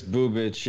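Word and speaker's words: Bubic: I'm pretty sure it's Boobitch. Yeah, it looks like it's a Croatian name. Those usually are pronounced itch at the end.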